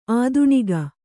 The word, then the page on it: ಆಧುನಿಕ: ādhunika